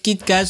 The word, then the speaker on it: Kit Kat: Au Japon, Kit Kat se prononce presque comme Kito Katsu, qui veut dire tu vas réussir à coup sûr.